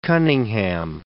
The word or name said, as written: Coningham